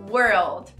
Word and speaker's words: world: Aqui eu quero praticar com você a pronúncia de world. Eu gosto de dar a dica de usar a palavra onde, que é where, com a palavra velho, que é old. Where, old, fica world.